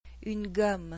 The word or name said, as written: une gomme